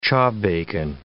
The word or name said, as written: chawbacon